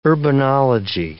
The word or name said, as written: urbanology